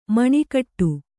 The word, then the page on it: ಮೊನೆಗೊಡು: monegoḍu